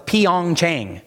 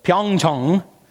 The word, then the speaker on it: Pyeongchang: This year at the Pyeongchang 2018 Olympics, I always thought it was Pyeongchang, but as I was watching news about the Olympics this week, I heard the locals pronounce it, and it's Pyeongchang, and I think that's pretty close.